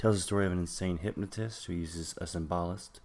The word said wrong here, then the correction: somnambulist: a somnambulist.